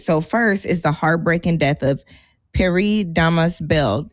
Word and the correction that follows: Pierre: Does it sound like I'm pronouncing that correctly? – Pierre. – Pierre Bell. You say he Haitian, that's Pierre for sure.